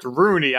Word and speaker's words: Rooney: So it just sounds like three O's. It sounds like Rooney. And I'm like, your name's Rooney? Like, no, it's Rooney. I have to really land on the R hard right there to pronunciate it.